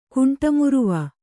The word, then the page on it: ಕುಂಟಮುರುವ: kuṇṭa nyryva